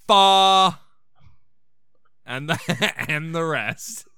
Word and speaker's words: fa: I want the waiter to, like, correct my pronunciation and go, it's do, re, mi, fa.